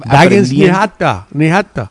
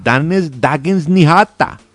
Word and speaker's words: Dagens Nyheter: Yo estoy Dagens Nyheter. – Ah, perdón, Dagens Nyheter. Bueno. – Eso. – Sí, tiene razón, oiga, ¿no? Es que sí, el mío era más de Malmo, esa pronunciación, pero bueno.